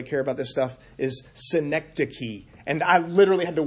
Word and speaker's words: synecdoche: I just this past week learned how to pronounce that word and worked to make sure I didn't say Schenectady. It's not the city in New York. It's synecdoche.